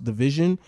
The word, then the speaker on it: dvsn: It's spelled DVSN, but it's pronounced Division.